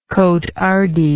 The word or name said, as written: cotehardie